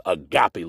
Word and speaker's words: agape: Now, if you look at it, it looks like agape, but it's pronounced agape love, A-G-A-P-E.